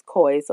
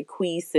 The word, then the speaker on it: Cois: It's pronounced as cois. A lot of people get the name mixed up. They say quees and things of that sort.